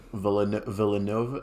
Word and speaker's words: Villeneuve: Villeneuve. Yeah, it's French.